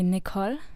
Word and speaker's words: Nicole: You're not English, so it wouldn't be necessarily the English way to pronounce it. But that's the right way to pronounce it. – Yeah, I would say normally Nicole. – Nicole. – So yeah. It's a bit different.